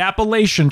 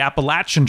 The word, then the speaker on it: Appalachian: It's not the Appalachian Trail, it's the Appalachian Trail. And he actually has a shirt that says it's Appalachian and it's spelled out that way.